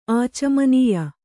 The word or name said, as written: ಆಚಮನೀಯ